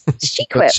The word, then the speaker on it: SheQuip: So it's spelled SheQuip. SheQuip. Like equip, but for girls. SheQuip.